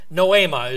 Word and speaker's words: noema: Noema is the way it would be pronounced.